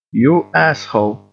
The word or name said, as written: you asshole